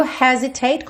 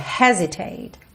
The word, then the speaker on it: hesitate: E o hesitate, como a gente falou lá na parte 2, a sílaba tônica dessa palavra é a primeira, hesitate.